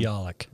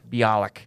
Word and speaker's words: Bialik: Bialik. – Bialik.